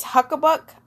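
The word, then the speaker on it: Habakkuk: It's Huckabuck. And it's in the Bible. If you go look at it, it's H-A-B-A-K-K-U-K.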